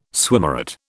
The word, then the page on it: swimmeret: British English